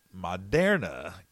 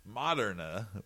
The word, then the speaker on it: Moderna: California temporarily stops injections of Moderna. Gosh, I can't say that right. Moderna. That's bad.